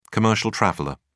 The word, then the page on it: commercial traveller: British English